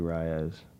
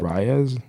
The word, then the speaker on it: Reyes: This has been The Demons featuring Mason Owens, DJ Merle, and Andrew Reyes. – You said Reyes? – My bad. – How you say your last name? – Reyes. – Okay. Mason Owens, DJ, and Andrew Reyes. Did I say it right? – Yeah.